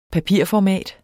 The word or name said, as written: papirformat